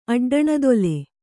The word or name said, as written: ಅಡ್ಡಣದೊಲೆ